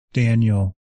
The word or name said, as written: Daniel